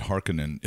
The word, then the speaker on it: Harkonnen: I thought I read something from the Herbert estate that said Harkonnen is right? – the correct pronunciation.